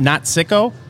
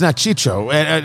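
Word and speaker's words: Nat Cicco: I think it's pronounced Nat Chicho. I could be wrong.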